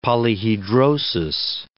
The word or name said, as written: polyhedrosis